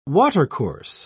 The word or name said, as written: watercourse